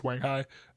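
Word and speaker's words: Wanghia: I actually asked one of my friends from China if that's how it's pronounced, so I do feel fairly confident that's pronounced Wanghai.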